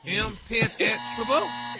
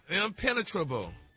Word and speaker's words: impenetrable: Impenetrable? – No, your emphasis Rock. Is on the wrong syllable.